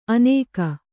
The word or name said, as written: ಅನೇಕ